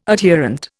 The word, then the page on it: adherent: Indian